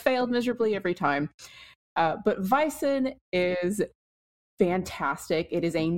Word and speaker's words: Vaesen: Am I pronouncing the name correctly? – Yes, I believe you are. This has been something that I jump around on. I suppose as the subject matter expert, I do have a background in Latin, Old Norse, Old English, and there's a bazillion ways to say that A-E, so it's spelled V-A-E-S-E-N, so I will probably say Vaesen, Vaesen, or some combination thereof.